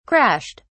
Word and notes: crashed: British English

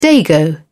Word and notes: dago: British English

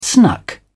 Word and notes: snuck: British English

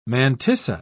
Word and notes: mantissa: British English

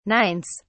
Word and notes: nines: British English

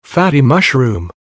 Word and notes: fatty mushroom: American English

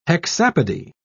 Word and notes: hexapody: British English

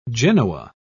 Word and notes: genoa: British English

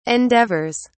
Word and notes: endeavours: British English

